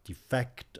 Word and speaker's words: defect: And suspect here, as indeed defect above, are both stressed on the second syllable, not, as we would do if they were adjectives, on the first.